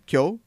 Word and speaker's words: Kyo: It also stars Machiko Kyo. You can correct on any of my pronunciations. – Machiko Kyo. – Kyo. – Yeah.